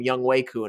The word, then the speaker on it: Younghoe Koo: On an earlier episode of this podcast this season, I referred to him as Younghoe Koo because if you look at how his name is spelled, that's how I would think it would be pronounced. But then I heard everyone calling him Younghoe Koo, and I'm like, oops, I've made a terrible mistake.